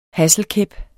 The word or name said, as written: hasselkæp